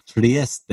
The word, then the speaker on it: Trieste: Yeah, it's in I Italy, can, but that's a really that's some really Trieste, good players on there. Trieste.